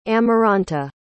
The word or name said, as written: amaranta